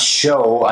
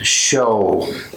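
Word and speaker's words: shew: And that word is pronounced show. I know it looks like shoe, but if you look it up in the English dictionary, it's pronounced show.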